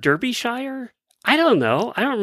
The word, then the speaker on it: Derbyshire: It's Derbyshire. She wanted to correct us on that.